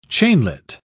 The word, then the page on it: chainlet: British English